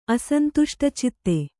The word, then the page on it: ಅಸಂತುಷ್ಟಚಿತ್ತೆ: asantuṣṭacitte